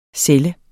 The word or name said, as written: celle